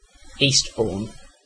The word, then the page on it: Eastbourne: /ˈiːstbɔː(ɹ)n/; British English